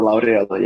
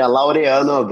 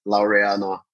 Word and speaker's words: Laureano: Cassiano Laureano, yes. Yeah, Laureano, but you know, in English you go with Laureano. It works just nice.